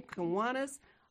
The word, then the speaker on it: Kiwanis: And she is the president, all hail the chief, of the of the Kiwanis Victorville of Victorville. Did I say that right? Kiwanis of Victorville.